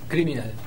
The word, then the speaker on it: Criminal: Criminal. – Acércate un poquito más, así te quedas en el plano del micrófono. – Criminal, en la primera sílaba.